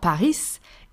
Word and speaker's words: Paris: It's not Paris, it's Paris. Paris. We do not pronounce the S at the end.